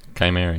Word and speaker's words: Chimera: So Chimera, is that how you say it? Chimera? – Chimera, yeah.